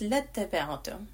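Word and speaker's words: lattaba'tum (لَاتَّبَعْتُمُ): And please note that there is a silent alif in the text here after the lam, and that silent alif is not meant to be pronounced. Okay, it's just there as a base, and we don't pronounce it. We connect the lam fatha directly to the ta mushaddad. So it's lattaba'tum.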